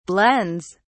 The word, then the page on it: blends: British English